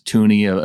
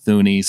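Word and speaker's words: Thuney: And I learned how to pronounce Tooney. Apparently, it's not Thuny.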